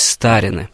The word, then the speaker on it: старины: Но вообще сами-то в народе назывались старины, именно с ударением на первый слог.